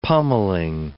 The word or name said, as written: pommelling